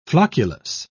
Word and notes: flocculus: British English

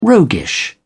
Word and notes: roguish: British English

